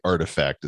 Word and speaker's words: RTFKT: RTFKT artifact is how you pronounce that as a collectibles startup, and they uh, they have been acquired by Nike.